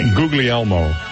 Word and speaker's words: Guglielmo: Guglielmo.